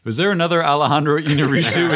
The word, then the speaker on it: Iñárritu: Like, it's Alejandro Iñárritu, if I'm pronouncing that correctly. – Iñárritu.